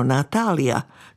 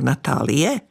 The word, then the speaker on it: Natália: Když si prý uvědomila, že se její jméno Natália tady spíš vyslovuje jako Natálie, nenamítala nic a zvykla si bez problémů.